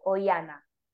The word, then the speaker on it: Oihana: Y en Instagram soy Cuerpo Emocional by Oiana, que mi nombre se escribe O y latina H A N A.